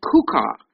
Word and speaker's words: chuqqah: So C-H-U-Q-Q-A-H, evidently pronounced Kuka.